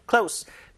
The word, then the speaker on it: close: close가 아니라요. close. 발음 잘하셔야 돼요. close.